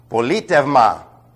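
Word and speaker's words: Politevma (πολίτευμα): Transliterated, P-O-L-I-T-E-V-M-A. Politevma. This is a diphthong, by the way. When combined, it comes out ev. It's an epsilon and an ypsilon, which is very difficult to pronounce, and therefore the Greeks simply made a contrast. They made it a V sound. It's the same at the beginning of the word evangelio, which we translate evangel, gospel. Not u. It's not paletu u.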